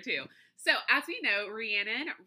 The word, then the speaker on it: Rhiannon: Another one of my absolute favorites, and it kind of kills me that I can never say her name correctly. I always want to say Rihanna. No, that was right. Rhiannon.